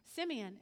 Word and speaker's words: Simeon: Is that pronounced that right, Simeon? Simeon.